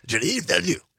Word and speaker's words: Denis Villeneuve: That's from the soundtrack of Dune, the movie by, I'm probably going to butcher this name because he's French-Canadian, directed by Denis Villeneuve, something like that. – You have to say it quickly with a lot of throat and tongue. Denis Villeneuve. Like that.